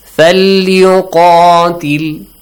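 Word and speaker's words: فليقاتل: So, for example, here, the letter Qaf is there. Whenever they have Fatha or Alif, Mad, A sound, okay? Falyuqatil. This, look. Qatil. You don't read it. Falyuqatil. So, this is a heavy letter.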